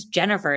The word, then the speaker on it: Jenifer: My name is Jennifer. It's spelled J-E-N-I-F-E-R.